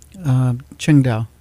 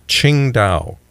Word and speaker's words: Tsingtao: And speaking of beer, how do you pronounce this Chinese beer name? – Qingdao. – Qingdao. Okay, Tsingtao. Okay, but it's Qingdao.